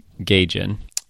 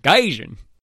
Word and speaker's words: Gaijin: I think that's, I mean, it's the most often said word in the movie is Gaijin. – That's true. – Did I say that right? – Well, certainly you said it better than Sean did repeatedly. – Gaijin.